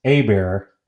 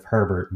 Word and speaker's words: Herbert: And apologies if I stutter over his name, because coming from Louisiana, the way his last name is spelled, that's sometimes pronounced Abear, where I'm from. And so I am constantly tempted to pronounce it as Frank Abear instead of Herbert, but I will do my best.